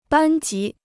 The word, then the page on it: 班级: bān jí